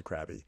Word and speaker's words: Krabi: So now the guests arrive at Krabby Beach, and they're there, and I just like that there's a beach called Krabby. Even though it's not spelled like Krabby, it's spelled K-R-A-B-I, but I just like, I like a good Krabby beach.